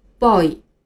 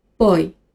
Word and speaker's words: boy: 发音的时候千万不要读成 boy 或者是 boy。再来和我一起读一次 boy。